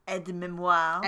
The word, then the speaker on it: aide-mémoire: Aide-mémoire.